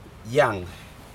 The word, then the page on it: yag: Aneityum